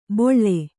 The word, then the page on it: ಬೊಳ್ಳೆ: boḷḷe; kannada